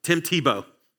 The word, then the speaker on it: Tebow: His last name rhymes with Bebo. Good job. Tim Tebow.